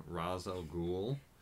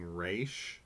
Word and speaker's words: Ra's al Ghul: I also listened to a Geek History Lesson on Ra's al Ghul. – Yay, you said it right. – Because in the movie they keep saying Ra's.